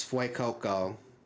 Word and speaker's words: Fuecoco: The fire type is Fuecoco, which, you know, depending on how you pronounce it, Fue, like Fuego, like hot Spanish.